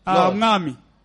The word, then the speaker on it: Mwami: How do you say Lord? – Mwami. – Mami. – Mwami. – Mwami. – Mwami. – Mwami. – Yeah.